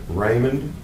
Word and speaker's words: Raymond: Raymond, R-A-Y-M-O-N-D. Last name, Palladino, P-A-L-A-D-I-N-O.